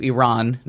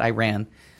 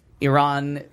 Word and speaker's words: Iran: And I'm saying it wrong too. Iran, not Iran. Iran threatened to sue.